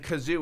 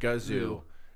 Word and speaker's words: Gazoo: are calling him Kazoo, and he's like, no, it's the Great Gazoo.